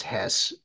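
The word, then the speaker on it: Höss: Yeah, I just want to say that the actually, I think it's pronounced Hess because it's H. It's like HO with an umlaut, SS.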